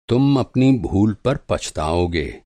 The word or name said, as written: पछताना